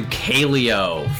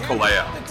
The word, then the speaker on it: Kaleo: And thank you to Kaleo for the intro music, their song No Good. You like this tune? – It's actually pronounced Kaleo.